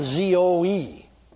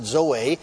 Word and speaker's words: zoe: Now, then, this particular one here is spelled Z O E. Some call it Zoe, some pronounce it Zoe. I pronounce it Zoe.